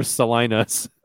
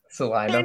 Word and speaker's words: Salina: You said it wrong again. – Yeah. Anything for Salinas. – Salina. – Anything for Salinas. – I've never heard somebody say Salina in my life. – That's how you say it. – I mean, you've been gone too long. – Those are listening, it's Salina.